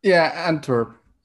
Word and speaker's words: Antwerp: And was it Antwerp? Is that how it's pronounced? – Antwerp. Yeah.